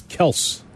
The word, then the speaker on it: Kelce: Travis Kelce. – Which is his correct pronunciation of his name for everybody out there.